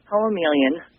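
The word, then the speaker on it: Poemeleon: It's pronounced Poemelian, like poem and chameleon smashed together.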